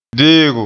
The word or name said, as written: deego